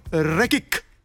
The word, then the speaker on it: Rekik: Allora, la regola vuole che si pronunci Rekik. – Perfetto. No. – La tua era perfetta. – Messo in dubbio, esatto. – La tua era perfetta italiana, ma perfetta. La mia è più tendente al berbero, capito?